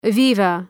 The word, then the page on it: viva: ‘vaıvə